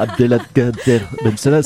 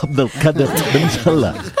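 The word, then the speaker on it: Abdelkader Bensalah: Pardon, Julie, mais vous, pardon de vous reprendre, je n'aime pas faire cela avec camarades, mais vous prononcez mal, Julie. C'est pas Abdelkader Ben Salah, c'est Abdelkader Ben Salah.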